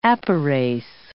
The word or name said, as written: apyrase